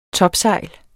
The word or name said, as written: TOPSEJLENES